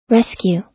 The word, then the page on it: rescue: American English